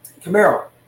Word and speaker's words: Kumerow: I think um, Camaro, Camaro. That's how you technically say it.